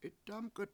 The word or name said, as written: 7itdAmk1t